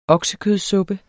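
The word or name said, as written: OKSEKØDSUPPE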